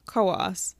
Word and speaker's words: Coos: Spelled Coos, but pronounced Coos. Don't question it.